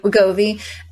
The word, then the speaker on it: Wegovy: Wegovy.